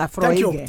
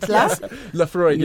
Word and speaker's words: Laphroaig: Know Laphroaig. – Thank you. Yes, that's how you say it. – Laphroaig.